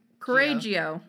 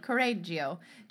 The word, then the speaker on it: Correggio: Correggio. Correggio. Cianciulli opened up a... – Well, the double R, you like roll it, but I'm not gonna. – Correggio.